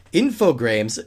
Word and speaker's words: Infogrames: Infogrames doesn't rhyme with games.